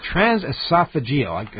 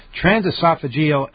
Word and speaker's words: transesophageal: Transesophageal. I couldn't do that. Transesophageal echocardiogram.